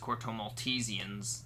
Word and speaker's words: Corto Maltesians: It was. That's officially how you say it. It's Corto Maltesians.